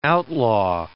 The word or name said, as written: outlaw